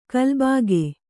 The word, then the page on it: ಕಲ್ಬಾಗೆ: kalbāge; Kannada